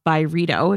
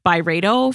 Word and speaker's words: Byredo: And it's actually a candle collab with Ben Gorham himself, who's the founder of Byredo, if you guys are not familiar. And by the way, Carlene, we've been pronouncing it Byredo for how long? It's actually Byredo.